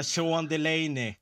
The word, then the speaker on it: Sean Delaney: Jean Delany. Jag tror det uttalas så. – Vem är det? det var han, han menar Sean Delaney.